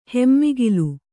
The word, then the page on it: ಹೆಮ್ಮಿಗಿಲು: hemmigilu